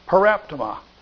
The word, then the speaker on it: paraptoma: Okay, now, this word "faults" in verse 16 is the Greek word paraptoma, P-A-R-A-P-T-O-M-A is how it's pronounced.